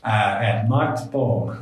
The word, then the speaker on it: Mart Poom: And she was like, that's not how you say his name. Mart Poom. There we go.